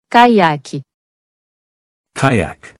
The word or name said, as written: Caiaque